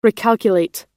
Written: recalculate

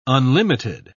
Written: unlimited

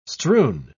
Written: strewn

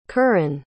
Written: kerrin

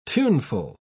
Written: tuneful

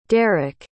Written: derrik

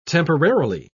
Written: temporarily

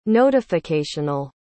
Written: notificational

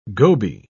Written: goby

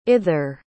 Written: ither

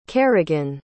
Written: carrigan

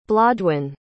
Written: blodwen

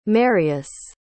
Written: Marius

